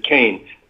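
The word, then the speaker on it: Kean: Anyway, yes, Leslie Keen, she pronounces it Keen.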